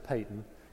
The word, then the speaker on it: Paton: John G. Patton, P-A-T-O-N. Some people pronounce it Patton.